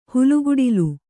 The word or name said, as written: ಹಾಲ್ಗಡಲು